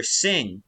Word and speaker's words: Singha: I went to a bar and ordered a beer sing because that's how it's pronounced, but it's spelled S-I-N-G-H-A, and so the bartender kind of sneered at me and said, you mean a singha?